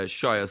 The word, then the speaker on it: Shia: Yeah, Shia.